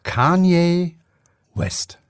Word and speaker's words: Kanye West: Es wird wirklich ausgesprochen Kanye West.